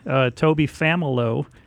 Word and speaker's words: Toby Familo: Toby Familo is how you pronounce it.